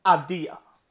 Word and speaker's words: Avdija: And that's how you pronounce it. I found that out this morning. Avdija.